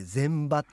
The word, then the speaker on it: 前場: うん、ちなみに株式を売買できる時間というのは午前と午後の 2 回あるんですけれども、午前中の立ち会い時間を前の場と書いて前場って、午後を後ろの場と書いて後場と言います。